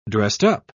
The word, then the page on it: dressed-up: British English